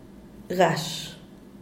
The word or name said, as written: gash